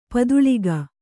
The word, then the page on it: ಪದುಳಿಕೆ: paduḷike; Kannada